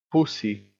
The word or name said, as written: pussy